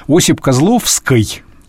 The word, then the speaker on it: Осип Козловский: Осип Козловский, да? – Да. – Именно так произносится.